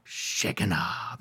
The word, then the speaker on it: Shekinah: Now, it's either, in English, it's either Shekinah or Shekinah. That's the English or the American English pronunciation. But I like the Hebrew pronunciation better. You need to say it with me to get the full effect. Shekinah, that's how they say it, Shekinah.